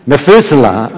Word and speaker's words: Methuselah: Enoch gave birth to, how to pronounce it? Methuselah? Methuselah, okay.